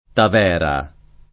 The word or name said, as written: Tavera